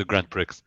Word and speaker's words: Grand Prix: Oh, sorry, the Grand Prix. – How have I never pronounced the Grand Prix? – I just think it's like elitist and exclusionary not to pronounce the X, you know?